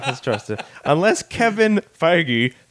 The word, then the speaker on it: Feige: I thought it was Feige. – Feige. – Is it Feige? – It's pronounced Feige. – Really? – It's spelled Feige, but it's pronounced Feige.